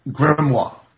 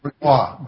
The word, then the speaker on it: grimoire: And there's a word in magic called grimoire. – Say that word again. – Grimoire. Grimoire. It's a French word.